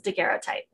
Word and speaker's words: daguerreotype: This is daguerreotype.